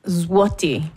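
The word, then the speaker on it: złoty: Ich glaube, es sind Slotty, oder? – Zloty. Ausgesprochen.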